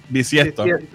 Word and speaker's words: bisiesto: Siempre lo digo mal. – Sí. Bisiesto.